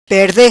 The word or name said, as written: Perde